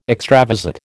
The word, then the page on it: extravasate: American English